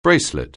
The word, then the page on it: bracelet: anglais